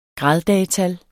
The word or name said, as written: graddagetal